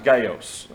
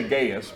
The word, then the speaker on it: Gaius: The name is pronounced Gaios, but we say Gaius because that's how it looks. That's how we would say it in English. So we're going to say Gaius because otherwise we'll get confused.